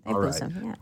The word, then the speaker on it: Thaipusam: He's going to be taking us on an adventure to Malaysia today to learn about the Tamil Hindu festival of, I hope I say this right, Thaipusam. Let me know if I'm saying that wrong. – You're saying it right. – Thaipusam? – Thaipusam, awesome.